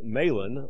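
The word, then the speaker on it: Mahlon: Malan or Malan, depending on your pronunciation, and Chilion, Ephrathites of Bethlehem, Judah, and they came into the country of Moab and continued there.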